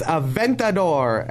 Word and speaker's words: Aventador: It's Aventador.